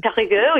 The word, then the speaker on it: Périgueux: Périgueux, yeah, that's perfect.